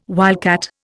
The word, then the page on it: wildcat: Indian